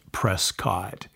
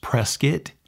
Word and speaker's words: Prescott: If you ever do want to move here, one hot tip I'll tell you is it is not pronounced Prescott. If you say Prescott, everybody's going to know you're not from around here. It's pronounced Prescott, Prescott. It's kind of like if you press and you have a kit. It's Prescott. People tell you it's like biscuit, but it's not Prescott, it's Prescott.